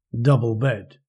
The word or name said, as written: double bed